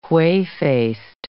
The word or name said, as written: whey-faced